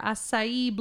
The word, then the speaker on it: acai: This acai? – That's how you say it. – It's acai, isn't it? – No, okay. – Acai. – All right, sorry.